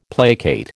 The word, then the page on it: placate: American English